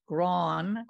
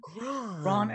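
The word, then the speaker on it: Grahn: My family always pronounced it Gron. – Gron, and you know, it has a meaning.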